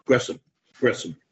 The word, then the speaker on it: Gresham: And you said that's actually supposed to be pronounced Gresham.